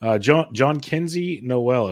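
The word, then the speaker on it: Jhonkensy Noel: And as you mentioned, John Kenzie Noel, as I've been told is the proper way to pronounce it, John Kenzie Noel of the Cleveland Guardians, came in.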